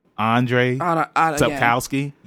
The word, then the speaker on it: Andrzej Sapkowski: So yeah, so The Witcher, it was created by Andrzej Sapkowski. Did I say it right? Andrzej Sapkowski. Yeah, that's right.